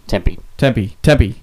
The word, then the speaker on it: Tempe: Over, Tempa? – Tempe. – Sorry.